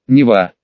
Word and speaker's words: Нева: Интересно, а почему озеро Нева, а река Нева? Этот вопрос задала уже Вера, немного удивившаяся тому, что и сейчас река носит то же имя. Правда, ударение в названиях сейчас делалось на «е».